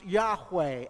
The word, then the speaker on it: Yahweh: That's the word that is pronounced Yahweh and is the most common name in the Old Testament for God.